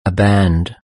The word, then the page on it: a band: British English